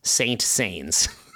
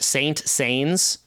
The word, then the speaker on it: Saint-Saëns: Camille Saint-Saëns was racked with pains when people addressed him as Saint Saëns. So in our language, it looks like it's spelled Saint Saëns. Saint-Saëns. – Saint-Saëns. Gotcha.